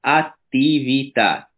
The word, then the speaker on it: attività: Потом есть другие маленькие моменты с произношением, как, например, э, смягчение буквы «д» и «т» перед буквой «и». Ну, например, есть слово э, упражнение или действие, которое в итальянском языке будет активита, uh Uh-huh. – Э, произношенный шарф «attività», «attività».